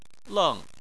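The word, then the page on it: leng: tone 4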